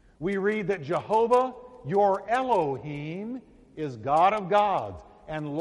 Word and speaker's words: Elohim: And the pronunciation is right there, Elohim.